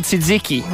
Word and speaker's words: tzatziki: It's called tzatziki, right? – Tzatziki, right? – Okay. – TZ, right? – Silent T.